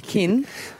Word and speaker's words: Qin: Is it kin, Q-I-N, how do you say that word in... – kin? – The kin ceramic collection at Parliament House.